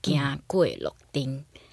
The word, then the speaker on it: 行過洛津: 所以我觉得行呃行过洛金其实有这样一点呃嗯，应该怎么讲，美梦成真的感觉哦。呃，不过讲到这一出戏，其实说实在，我一开始听到这个名字“ 行过洛金”，呃呃，我想问一下，这个台语怎么念？ – 嗯、呃，一般漳泉混合腔应该会讲“ 行过洛丁”。